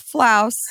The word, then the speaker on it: Flaus: So kind of like house, spelled sort of like that word floss, but it's actually pronounced Flaus. So F-L-A-U-S.